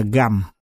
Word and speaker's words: Gầm: Vì chữ Hán không có chữ tương đương nên viết chữ Sầm đọc là Gầm.